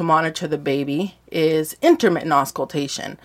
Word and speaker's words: auscultation: Can you pronounce it for me? – It's intermittent auscultation.